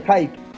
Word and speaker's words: hype: Lo, muy gracioso de este juego es que el juego, previo a su salida, viste que se habla mucho del hype, el, IP, sí. – ¿viste? La, excitación previa a la salida de algo. Se escribe H-Y-P-E, e, hype. Hype.